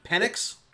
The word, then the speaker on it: Penix: Do you know how to pronounce the Indiana quarterback's last name? – Let's see what I can find here. – How do we pronounce the E? Well, we go back. – It's about P-E-N-I-X. – Hit the wrong button. – Is it Penix? – Penix. It's got to be Penix, dude.